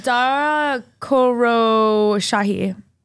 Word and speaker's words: Dara Khosrowshahi: Dara Khosrowshahi.